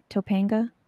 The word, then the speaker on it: Topanga: Topanga? – Yep.